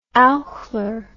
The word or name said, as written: Eichler